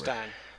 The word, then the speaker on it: stein: Say it Steam, some say Stein, whatever. – It's Stein.